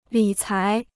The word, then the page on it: 理财: lǐ cái